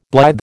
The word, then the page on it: blithe: American English